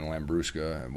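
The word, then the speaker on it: Labrusca: And then Lambrusca, which I butchered that word occasionally, sorry.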